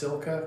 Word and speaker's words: Cilka: Later, when I got home and looked at it more closely, it says the book is called, I think it's pronounced Silka, because it's spelled C-I-L-K-A.